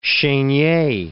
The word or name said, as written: Chenier